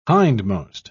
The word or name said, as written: hindmost